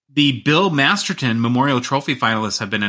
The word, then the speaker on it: Masterton: I stayed up all night trying to pronounce it because every time I've read that name for an award, I say Masterson. I don't know why. It's just how I am. I know it's Masterton, but I have been corrected many a time on that.